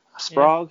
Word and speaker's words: Sprague: I mean, Ed Sprague. Sprague? I have never heard of him before. – Sprague? – Sprague. Yeah. It's spelt the same way as Prague, isn't it? So let's say Sprague.